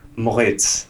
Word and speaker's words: Moritz: I'm your host, and today we've got a very special guest, Moritz.